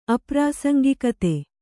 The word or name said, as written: ಅಪ್ರಾಸಂಗಿಕತೆ